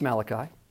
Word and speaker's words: Malachi: It is pronounced Malachi.